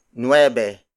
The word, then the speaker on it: nuwebe: Ocho pesos is spelled as O-T-S-O space P-E-S-O-S. Again, the Tagalog Spanish word for 8 is ocho. The next number is nueve. Nueve. Nueve. Nueve is spelled as N-U-W-E-B-E.